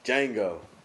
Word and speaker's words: Django: Django. The nigga is silent. I mean, the D is silent.